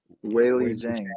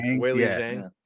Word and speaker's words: Weili Zhang: Weili Zhang. – Weili Zhang.